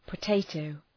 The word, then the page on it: potato: pə’teıtəʋ